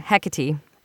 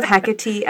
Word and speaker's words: Hecate: Hecate. Before the internet, I didn't know. – I love Hecate as well because it's three syllables and she's the triple goddess.